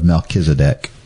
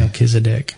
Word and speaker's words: Melchizedek: Melchizedek. – You did good with that name. I don't know if I've ever pronounced it that way. That sounds like the right way, though.